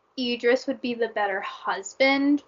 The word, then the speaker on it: Idris: No, that is how I always called him Idris. Apparently, it's Idris, so I'm sorry.